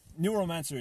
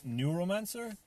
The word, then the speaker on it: Neuromancer: Neuromancer is a song on it, like I said. He does this great thing. See the way it's spelled N-E-U-R-O, neuro as in neurological.